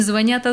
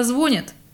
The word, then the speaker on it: звонят: У нас на районе не звонят, а звонят.